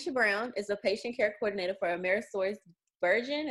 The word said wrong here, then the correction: Bergen: Is that how you pronounce it? Bergen.